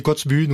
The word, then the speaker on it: Kotzebue: Alors, c'est pas le Spitzberg, c'est l'Alaska, alors, je sais pas comment il faut prononcer ça, 1999, bientôt 30 ans, Kotzebue. – Oui, on le dit Kotzebue, nous aussi. – Kotzebue.